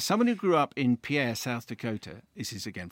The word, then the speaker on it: Pierre: Incidentally, Pierre, South Dakota is pronounced Pierre. So it's written as in the French for Peter, but it's pronounced P-I-E-R, as in a boat dock.